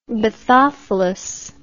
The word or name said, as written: bathophilous